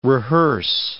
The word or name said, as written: rehearse